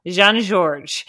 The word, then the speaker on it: Jean Georges: Jean Georges.